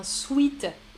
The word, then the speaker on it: sweat: Mais la majorité des Français disent un sweet ou un sweatshirt. Donc on, ne prononce, on prononce mal hein, le mot anglais qui est sweat, sweatshirt. Nous on dit un sweat, un sweat ou un sweatshirt. voilà. Donc si vous voulez prononcer à la française, vous direz un sweat.